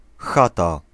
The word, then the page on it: chaumière: France; /ʃo.mjɛʁ/